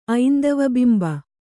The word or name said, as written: ಐಂದವಬಿಂಬ